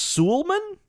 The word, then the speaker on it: Suleman: I'm going to say it's pronounced Suleiman. S-U-L-E-M-A-N.